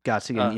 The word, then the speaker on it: Gottsagen: And one of the things that I do know is that somehow they, I guess, had a relationship with Zach Gottsagen. I think that's how you say it. – Gottsagen, yeah.